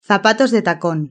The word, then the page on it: zapatos de tacon: European Spanish